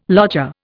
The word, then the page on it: lodger: Indian